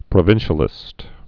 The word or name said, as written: provincialists